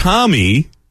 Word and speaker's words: Homme: Yeah, by the way, I was going to bring it up because Josh Homme, that's his name, right? Is that how you pronounce it? – Yes.